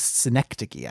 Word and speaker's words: synecdoche: So Locklist is sort of like a synecdoche for Virginia. Is that how it's pronounced? – I think it was synecdoche. I think you got it, but you put the stress on the wrong side.